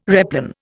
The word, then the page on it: replan: Indian